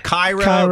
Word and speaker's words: Cairo: I mean, somebody sees C-A-I-R-O, and they say what? Cairo. But we say, oh, no, that's Cairo, man.